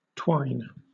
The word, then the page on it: twine: British English